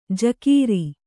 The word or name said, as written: ಝಕಾರ